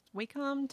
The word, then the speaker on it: Wacom: That's actually what we've started to do, because he broke out the Wacom tablet thing. – Yeah, Illustrator. – They like you to pronounce it Wacom.